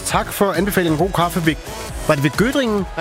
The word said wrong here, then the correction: Göttingen: Nej, Göttingen.